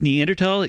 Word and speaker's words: Neanderthal: Yes, it's pronounced Neanderthal. It comes from the Neander Valley of Germany.